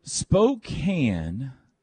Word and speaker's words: Spokane: Spokane, which I believe we have settled on the pronunciation. – Yes, yes. Not Spokane.